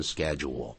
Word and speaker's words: schedule: Everyday schedule 后头放了个名词 SCHEDULE SCHEDULE 如果在英式英语学惯的话，这个字不念成 schedule 英语啊，英式英语念成 schedule schedule 好，但是老师美式英语加拿大英语啊，包括澳洲的啦，包括纽西兰，他们还是念成 schedule。